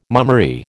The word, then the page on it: mummery: American English